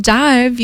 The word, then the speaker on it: DIIV: And the band Dive, you spell it D-I-I-V, is for fans of bands like Nothing, Slow Dive, My Bloody Valentine, Ride, Soft Cult, and more.